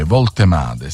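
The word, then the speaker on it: Woltemade: Voltemade, sì, si pronuncia così.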